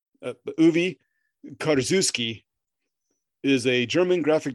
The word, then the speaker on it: Uwe: U W E. – Um, U. – The V might be pronounced as, or the, the W might be pronounced as a V.